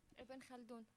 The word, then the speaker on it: Ibn Khaldun: Now, we have a friend from Palestine, and she will do the correct pronunciation for us. – Ibn Khaldun.